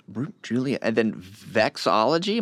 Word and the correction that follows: vexillology: am I saying that right? Vexillology. – Vexillology.